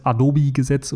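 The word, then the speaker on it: Hadopi: Adobe-Gesetz oder Hadobe-Gesetz, je nachdem, wie man es aussprechen möchte. Die Franzosen sprechen es natürlich ohne H aus.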